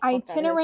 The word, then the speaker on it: itinerant: I have no idea what the itinerant fuck itinerant laborer. I looked it up. Even though I can't pronounce it, I did look it up. – It looks like the word itinerary, but it's itinerant.